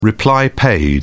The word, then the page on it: reply-paid: British English